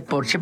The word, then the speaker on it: Porsche: O decir que tengo un Porsche en lugar de decir tengo un Porsche, porque en alemán Porsche se dice Porsche porque todas las es al final se pronuncian como Marlene Dietrich, por ejemplo, que todo el mundo dice Marlene, pero es Marlene.